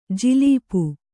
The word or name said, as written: ಜುಲ್ಪಿ